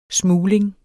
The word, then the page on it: smugling: ˈsmuːleŋ